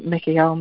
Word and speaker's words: Mikael: But especially when with the pronunciation of the L part of it, L is an ancient Hebrew name for God. So Mikael, Mikael, Mikael is the invocation of that light,